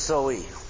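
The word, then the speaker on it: Zoe (ζωή): Life in the Greek is Zoe. Is that how it's pronounced today? – Perfect.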